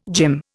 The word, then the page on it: gym: Indian